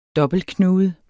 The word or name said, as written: dobbeltknude